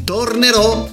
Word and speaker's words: tornerò: Arriva la generazione X con gli Homo Sapiens, un grande gruppo degli anni 60-70 in Italia, con il brano Tornerai, tornerò, con l'accento sulla O. È giusto, se tu tornerai, tornerò anch'io, ma se tu non tornerai, non tornerò anch'io.